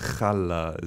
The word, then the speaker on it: challah: Yeah, it looks like challah when you when you read it, but it's challah is how you say it.